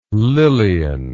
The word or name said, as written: lillian